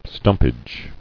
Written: Stumpage

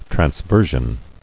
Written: Transversion

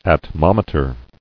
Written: Atmometer

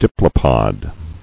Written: Diplopod

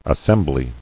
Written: Assemblies

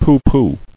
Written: Pooh-pooh

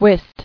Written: Wist

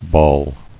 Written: Balling

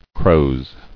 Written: Croze

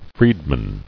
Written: Freedmen

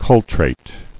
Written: Cultrated

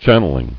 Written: Channeling

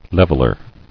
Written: Leveler

